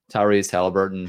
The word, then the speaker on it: Tyrese Halliburton: My personal favorite, Tyrese Halliburton, who hopefully I'm not butchering his name.